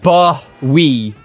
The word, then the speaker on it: Bowie: His stage name is pronounced Bowie, to rhyme with Doughy or Joey. – Bowie! I will go see David Bowie.